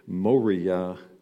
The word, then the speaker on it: moria: The Greek term in the New Testament time for foolishness, I only pronounce it because it's connected to an English word, moria.